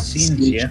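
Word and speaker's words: Scientia: Now this place, Magus Seentia, Seentia, it's a S-C-I-E-N-T-I-A. And the first word is spelled M-A-G-U-S.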